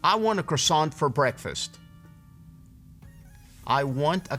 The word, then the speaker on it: croissant: Y esta, vea cómo se escribe. Y se dice croissant. Croissant. Croissant.